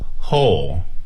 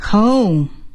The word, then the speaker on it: whole: Whole. – Whole. 整个。这个发音呢，希望大家尽可能向英语的标准发音走，叫做 whole 而不是 whole，不是中式的，而是 whole 整个的。